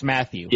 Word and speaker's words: Mathieu: His last name is literally pronounced Matthew. – Yeah, it's just Matthew.